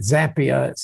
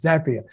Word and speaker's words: Zapier: And right on the side of it, it says, by the way, here's how you say it: Zapier, same as happier, right? It is Zapier.